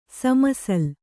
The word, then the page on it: ಸಮಸಲ್: sama sal